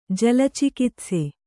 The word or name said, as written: ಜಲಚಿಕಿತ್ಸೆ